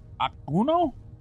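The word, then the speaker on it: Okuno: Akuno? – Oh, yeah, you nailed it.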